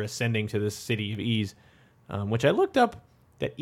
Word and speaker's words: Ys: And I don't really remember the details of that, but yeah, there's some pre-existing like YS, and I guess I should also point out that yes, the pronunciation of the game is Ys.